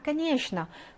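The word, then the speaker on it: 카니에슈나 (конечно): 나 카니에슈나. 그럼 카니에슈나 당연하지. 자, 여기 발음 어떻게 되죠? 이렇게 되죠. 슈또 하고 가니에슈노에서 최가 샤 발음이 나죠?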